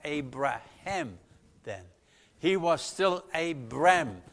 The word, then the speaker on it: Abraham: And there's this H in there. And in Hebrew, you pronounce it also. And Abraham.